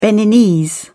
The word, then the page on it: beninese: British English